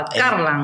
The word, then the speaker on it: Carlan: Con la tónica en la primera sílaba, Carlan.